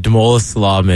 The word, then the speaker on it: Damola Salami: Did I say that right? – Yeah, you did. Damola Salami.